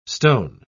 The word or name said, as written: stone